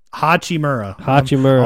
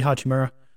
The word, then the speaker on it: Hachimura: Lakers traded for Rui Hachimura. – Hachimura. – I was adding an extra syllable there. Rui Hachimura.